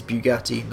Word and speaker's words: Bugatti: Ignore how he pronounces it, and even though it's his song, ignore it. It is Bugatti, not Bugatti.